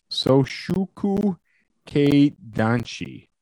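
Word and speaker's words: soshokukeidanshi: In Japan, they're called soshuku keidanshi.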